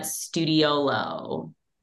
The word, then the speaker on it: Studiolo: Did I say that wrong? – No, you didn't, but it's okay. Studiolo is an Italian architecture term for a little studio.